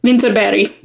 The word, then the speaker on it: Vinterberg: Vinterberg. – Oh, that's amazing. Sorry, continue. – Yeah. Where you see Berg, it's usually pronounced Berg in Scandinavia.